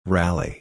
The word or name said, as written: rally